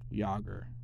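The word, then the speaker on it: Jaromír Jágr: It's Jaromir Jagr. Jagr, right? Not Jagermeister, fucking people.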